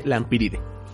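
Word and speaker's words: Lampyridae: Son unos insectos del orden Coleoptera, mismo orden al que pertenecen los escarabajos de la familia Lampiridae, que se escribe Lampiridae, con una Y después de la P y con terminación AE, pero que se, lee Lampiride.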